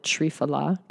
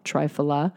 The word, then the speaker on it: Triphala: It is made up of three different herbs, which is why they call it Triphala, Triphala.